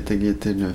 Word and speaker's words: tagliatelles: Je fais des spaghettis et des tagliatelles fraîches aux œufs. – Hein? Je prononce pas le G. Tagliatelles.